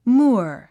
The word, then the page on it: moor: múə r; ムゥア